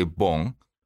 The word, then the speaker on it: Bon: Well, in Japanese language, for us, we say Bon. In Japanese language, the O in front of it is kind of like an honorific.